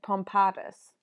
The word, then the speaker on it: pompatus: However, it is stressed on the second syllable, whereas the nonce word is stressed on the first. – Pompetus and pompatus.